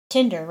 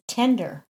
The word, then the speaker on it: Tinder: Tinder, right? That's how I say it. – Mm-hmm. – Well, he said that his brother-in-law and his girlfriend pronounce it tender. – As in, like, gentle or soft? – Yeah, like tender loving care. – Hmm. What is how do you spell like legal tender? Is that T-E-N-D-I-R or is that T-I-N-D-E-R? – T-E-N, legal tender, yeah.